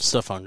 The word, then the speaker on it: Stephon: Speaking of brothers on the Lions, did you realize Stephen Gilmore's little brother is on the Lions? – Stephon Gilmore. – Or Stephen, but it's spelled like Stephen with a PH.